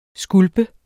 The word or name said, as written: skulpe